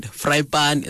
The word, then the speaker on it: Vrypaan: Vrypaan is how you pronounce it. – Yeah. – It means Friday. You know? Like Vrypaan.